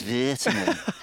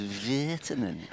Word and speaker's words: Virtanen: Do you know you say you have Virtanen. To say his name? It's Virtanen. Yeah, it can't be Virtanen. The only thing is Virtanen.